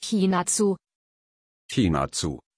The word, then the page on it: chinatsu: German